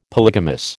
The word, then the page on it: polygamous: American English